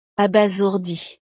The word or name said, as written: abasourdi